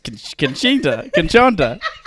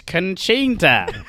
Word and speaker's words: Concetta: Well, it's, you know, it's Conchonta. But a lot of people, here's the thing, a lot of people think I'm saying my name is Coachella. And it's not. It's not Coachella. That's not a name. It's Conchonta. It's Italian.